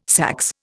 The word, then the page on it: saxe: Indian